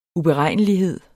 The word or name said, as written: uberegnelighed